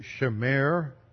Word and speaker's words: shemer: The Hebrew word for aged wine here is shemer. That's spelled S-H-E-M-E-R.